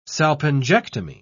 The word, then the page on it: salpingectomy: British English